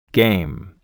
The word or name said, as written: game